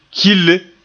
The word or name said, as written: kille